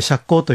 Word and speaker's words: 赤口: 一般的には仏滅と赤口と書いて釈光と読むんですが、はい、それ以外であれば大丈夫ですので、うん、神社にご相談いただければ日取りを決めることができます。